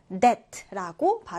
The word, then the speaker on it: debt: 이 단어에서 B는 발음하지 않고 묵음 처리가 된다. 그래서 debt라고 발음합니다.